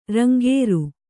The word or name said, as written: ರಂಗಾರೆ